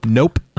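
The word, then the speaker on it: Knope: How do you pronounce the last name? – Nope. – That's what I thought. I really wanted the K to be silent.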